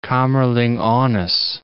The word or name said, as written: Kamerlingh Onnes